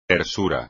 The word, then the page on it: tersura: espagnol